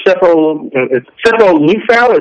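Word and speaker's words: Sefo Liufau: I know they're gearing up for Cepho Lufau. Is that, am I pronouncing that right? – Correct. Yep.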